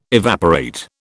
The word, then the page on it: evaporate: British English